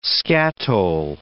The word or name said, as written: skatole